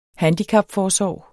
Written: handicapforsorg

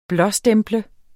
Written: blåstemple

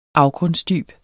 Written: afgrundsdyb

